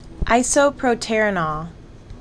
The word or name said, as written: Isoproterenol